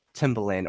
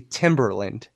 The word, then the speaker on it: Timbaland: If we're going to be if we're going to celebrate him, pal, shouldn't you pronounce his name correctly? – Sorry. This is what I see on social media a lot, Ed. Like I've seen Darnell Jones, but I also see Timberland.